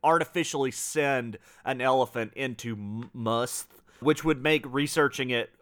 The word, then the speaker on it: musth: M-U-S-T-H. I think I'm saying that right.